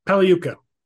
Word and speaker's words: Pagliuca: Pagliuca.